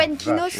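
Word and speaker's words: Foenkinos: Déjà, on va dès le début, tout de suite, enlever une vanne que j'ai subie hier, honteusement, parce que j'ai dit Fuenkinos, et vous avez dit, mais non, ça se prononce pas comme ça, les garçons. – Tu le dis parfaitement. – Bah voilà, c'est la bonne prononciation. – Fuenkinos, voilà. Ouais, et pas, et pas fun, je sais pas ce que tu m'avais inventé.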